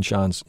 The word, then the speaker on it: Chaon: His name, by the way, is spelled C-H-A-O-N, so don't go to the S section.